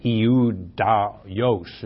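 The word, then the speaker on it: Ioudaios: Eudaios is how it's pronounced in the Greek.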